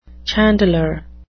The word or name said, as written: chandler